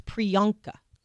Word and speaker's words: Priyanka: It's Priyanka.